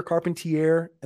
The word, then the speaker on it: Carpentier: Actually, no, because everyone pronounces the name wrong. Carpenter. What are they? Oh, Carpenter. – Carpenter, Carpentier, and they don't put it together. It's like, it's literally so easy. It's car, it's pen, and it's tier one.